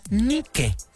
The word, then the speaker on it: Nike: La realidad es que la marca se pronuncia Nike. Porque viene del griego. Nike, la diosa griega de la victoria.